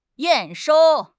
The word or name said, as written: 验收